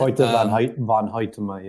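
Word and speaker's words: Hoyte van Hoytema: You brought up Hoyte von Hoytema, I think is how you pronounce it. – Hoyte von Hoytema, yeah.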